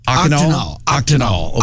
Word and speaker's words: octenol: O-C-T-E-N-O-L. – Akinol.